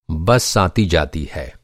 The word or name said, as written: आन